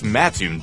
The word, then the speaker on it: Mattoon: It's Mattoon, dummy.